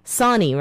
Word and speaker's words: Sonni: My name is pronounced Sonni, S-O-N-N-I. – Oh, I'm sorry. Thank you so much for the corrections. – Sure. – Sonni, right? – Right.